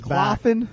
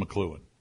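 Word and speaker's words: McCloughan: Yeah, McLuhan. It doesn't look like it should be pronounced McLuhan.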